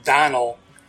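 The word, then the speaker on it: Domhnall: It's spelled weird, but it's pronounced Donald. It's spelled Dom H. Null. – Ugh. – The M and the H are silent.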